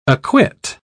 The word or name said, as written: acquit